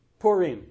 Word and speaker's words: Purim: Now, this word pur or pure, because I think the Jewish holiday is Purim, is how that's pronounced. Purim, okay.